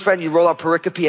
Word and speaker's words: pericope: It's spelled like pericope. I used to call it pericope, and then someone who was smart said, no, it's pericope.